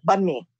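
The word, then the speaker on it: bánh mì: So the best pronunciation, so there are actually two pronunciations. One of them is the South Vietnamese way, which might be a little bit easier for you guys because it's close to what you see when you look at the word bun mi. – Yes. – And that's the way it's pronounced, bun mi, like bun, hamburger buns, bun mi.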